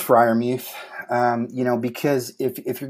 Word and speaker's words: Freiermuth: Freiermuth. – How is that? – Freiermuth. – Freiermuth.